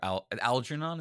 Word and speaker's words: Algernon: I really like the flowers for Algernon.